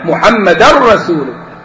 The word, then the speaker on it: محمدا رسول: فالتنوين بعده الراء إدغام بغير غنة، إدغام كامل، محمدا رسول.